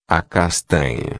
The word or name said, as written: castanha